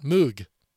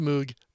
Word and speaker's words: Moog: which was developed by Robert Moog. He's pronounced Moog. The instrument is pronounced Moog. I don't know why that is, but I always found that funny.